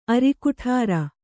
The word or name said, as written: ಅರಿಕುಠಾರ